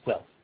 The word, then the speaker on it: Guelph: But Claude would like to correct you. It's Guelph.